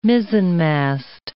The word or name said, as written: mizzenmast